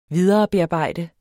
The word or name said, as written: viderebearbejde